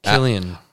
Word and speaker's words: Cillian: Is that how you pronounce his first name? Cillian? – Killian? – I don't think it's Cillian. – Okay, then. Sicilian. Killian Murphy, then. – Mm. Killian.